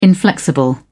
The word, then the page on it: inflexible: British English